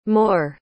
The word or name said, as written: Moore